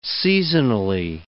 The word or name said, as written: seasonally